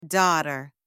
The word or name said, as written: daughter